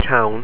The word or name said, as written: tonn